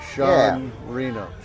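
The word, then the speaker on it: Jean Reno: Or Sean? I mean, he's French. I don't know how to pronounce that. – Sean Reno.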